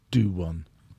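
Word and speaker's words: Duhan: For an opposition to know that like Dwayne Van Der Merwe is not great fielding kicks and Stuart Hogg's not great fielding. It's pronounced do one.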